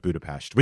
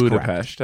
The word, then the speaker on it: Budapest: for saying I was going to Budapest, and then all of a sudden Hayes came out with Budapest, which is Budapest. – Correct. – Yeah, I guess I think it's actually respectful to pronounce it the way that they...